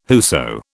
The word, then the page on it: whoso: British English